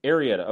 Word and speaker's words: Arrieta: And Arrieta, how do I say it correctly? – Arrieta. – Okay, good. I was right on the first track.